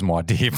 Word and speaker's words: Muad'Dib: I've only ever heard Muad'Dib pronounced as Muad'Dib, so it was really confusing to me when you said Muad'Dib.